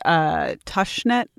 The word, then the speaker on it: Tushnet: I just wanted to note that it's pronounced Tushnet, like lush.